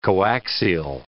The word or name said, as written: coaxial